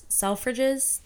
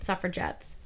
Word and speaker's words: Selfridges: I will say the department stores go so hard there, like Selfridges, Selfridges, that's how you pronounce it. I kept calling it suffragettes, Selfridges.